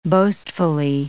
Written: boastfully

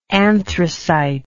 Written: anthracitous

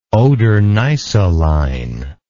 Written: oder neisse line

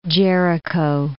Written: Jericho

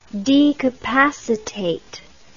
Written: decapacitate

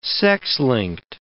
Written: sex-linked

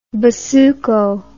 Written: basuco

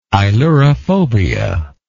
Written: ailurophobia

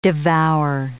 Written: devour

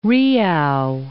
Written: Riouw

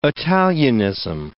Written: Italianism

